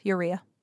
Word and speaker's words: urea: Yeah, urea.